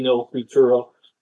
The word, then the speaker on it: Futuro: No Future Alert? – Futuro, yeah, it's in Spanish, so yeah, yeah. I'm butchering the pronunciation, but yeah, Rodrigo D. No Futuro.